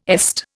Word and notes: EST: Indian

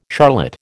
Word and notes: charlotte: American English

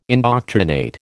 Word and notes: indoctrinate: American English

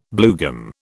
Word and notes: bluegum: British English

